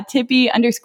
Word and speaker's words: Tepe: Yeah, Tippy _ Toes. And Tippy is spelled T-E-P-E, not like T-I-P-P-I, because I have a weird last name. – I love it, guys. Go follow Tori at T-E-P-E _ Toes, T-O-E-S, and show her some love over there.